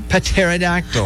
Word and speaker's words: pterodactyl: It's spelled with a P. Pterodactyl.